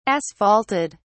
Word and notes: asphalted: British English

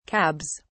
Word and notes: cabs: British English

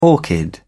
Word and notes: orchid: British English

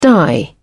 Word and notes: die out: British English

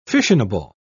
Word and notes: fissionable: British English

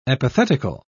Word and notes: epithetical: British English

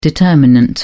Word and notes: determinant: British English

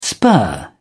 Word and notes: spur: British English

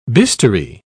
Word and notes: bistoury: British English

